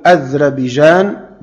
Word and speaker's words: أذربيجان: قال أن أنس بن مالك حدثه أن حذيفة بن اليمان قدم على عثمان وكان يغازي أهل الشام في فتح أرمينيا وأذربيجان، وأذربيجان تنطق هكذا أذربيجان وأذربيجان وأذربيجان، نقول أذربيجان بتسكين الذال معجمة وفتح الراء أذربيجان، وننطقها كذلك أذربيجان بفتح الهمزة والذال معجمة وتسكين الراء أذربيجان مع أهل العراق.